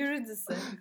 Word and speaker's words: Eurydice: Eurydice. Eurydice üzerinden yapmak çok da anlamlı değil ya.